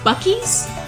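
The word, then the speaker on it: Buc-ee's: It's Bucky's.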